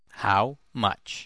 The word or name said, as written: how much